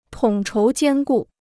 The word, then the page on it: 统筹兼顾: tǒng chóu jiān gù